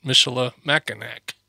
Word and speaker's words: Michilimackinac: And that's how they pronounced it. It's Fort Michilimackinac.